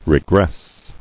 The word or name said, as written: Regressing